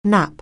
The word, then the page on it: nap: anglais